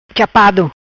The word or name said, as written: Chapado